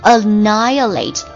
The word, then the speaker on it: annihilate: 今天晚上的唯一任务交给你的就是把这个单词记住 annihilate A-N-N-I H-I-H 不发音 Annihilating L-A-T-E Annihilate 它是动词，表示消灭、歼灭、毁灭的意思。